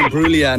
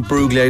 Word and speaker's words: Imbruglia: Oh, Natalie Imbruglia, not Bruglia. I don't pronounce the G.